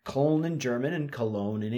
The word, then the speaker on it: Cologne: Now you can pronounce this the German way, Cologne, or the English way, Cologne.